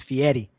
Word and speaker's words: Fieri: You gotta say Fieri. If you say Fieri, he's like, nobody's saying it right. It's Fieri.